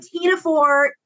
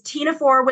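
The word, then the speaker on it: ctenophore: And tenophore is tenophore with a silent C in front.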